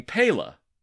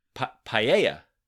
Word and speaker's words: paella: I'm making paella. Paella, Mark, it's paella.